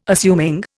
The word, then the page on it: assuming: Indian